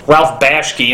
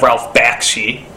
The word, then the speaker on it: Ralph Bakshi: There's been a couple of names that, for whatever reason, I could never quite pronounce correctly, like Judge Hirsch instead of Judd Hirsch, Ralph Bashke instead of Ralph Bakshi.